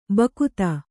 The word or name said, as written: ಭುಕ್ತಿ